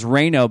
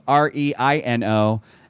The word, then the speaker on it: Rayno: Josh Timmelman is the singer-songwriter, and his Finnish family's name is Rayno, but it's spelled R-E-I-N-O. And after too many gigs with people saying Re-I-No or Reno, that and other funny pronunciations, they just spelled it for phonetics, Reno, R-A-Y-N-O.